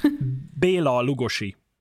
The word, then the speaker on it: Bela Lugosi: So this week, Bela Lugosi. I hope I pronounced that right. – Close, very close. – What? How would he be pronounced? – Bela Lugosi. But actually, I would say Lugosi Bela because he was Hungarian and Hungarians use the surname first.